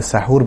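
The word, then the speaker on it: সাহুর (سحور): সাহরি মধ্যরাতের পরে করলে কোনো অসুবিধা নেই, তবে সাহরির ক্ষেত্রে, যেটাকে আরবিতে সাহুর বলা হয় মূলত, সাহার অথবা সাহুর, বাংলা যদি আমরা সাহরি বলি, শব্দটার আরবি উচ্চারণ হচ্ছে সাহুর, এই সাহুর যদি কেউ মধ্যরাতের পরে তিনি নেন অর্থাৎ পানাহার করেন তাহলে কোনো অসুবিধা নেই, যদি তিনি দেরি করেন সেটাই হচ্ছে সুন্নাহ।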